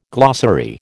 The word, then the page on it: glossary: American English